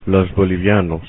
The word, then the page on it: bolivianos: Spanish